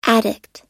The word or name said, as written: addict